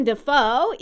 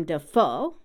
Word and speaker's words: Dafoe: So some examples of triplet names are Jennifer Aniston, Harrison Ford, Abraham Lincoln, Willem Dafoe, even though both first and last names are two syllables, it's a triplet rhythm because his last name is emphasized on the last syllable, Willem Dafoe.